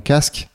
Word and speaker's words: Caask: Et donc casque, ça s'écrit C-A-A-S-K. Donc Iago, il y a un A, casque, il y a deux A en avance. C'est la, c'est ça, la contraction de candidate et ask.